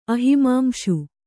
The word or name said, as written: ಅಹಿಮಾಂಶು